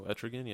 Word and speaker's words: Etrigan: Uh, I remember there was a Predator one, and I remember a specific one, and it was one with uh, I cannot pronounce his name, uh, Etrigan. – No, Etrigan, yeah.